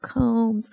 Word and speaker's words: Combs: He goes, well, maybe we'll go on Saturday and stay through the end of Sunday because I really want to see Luke Combs.